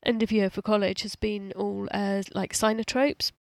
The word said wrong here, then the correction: cyanotypes: Is that how you say it? Cyanotropes. – Cyanotypes, yeah.